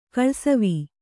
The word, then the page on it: ಕಳ್ಸವಿ: Kannada